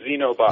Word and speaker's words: Xenobot: We'll take either, but we usually use Xenobot. – Xenobot, okay.